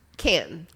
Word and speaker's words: Cannes: So when you're talking to your friends about how to pronounce this film festival, it's a city in France, and It's pronounced... – Cannes. – Can. – Can. – But if you look at the way that the K, I would go con. – I would go con too.